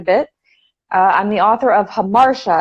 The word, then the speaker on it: Hamartia: She was like, it's Hamartia, not Tia.